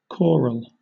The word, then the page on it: choral: British English